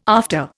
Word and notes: after: Indian

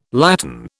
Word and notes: latten: British English

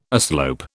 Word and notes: aslope: British English

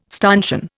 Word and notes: stanchion: Indian

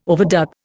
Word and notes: overdub: Indian

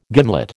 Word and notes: gimlet: American English

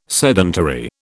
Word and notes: sedentary: British English

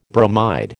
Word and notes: bromide: American English

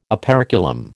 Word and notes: operculum: American English

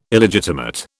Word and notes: Illegitimate: British English

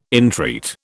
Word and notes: entreat: British English

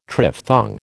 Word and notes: triphthong: American English

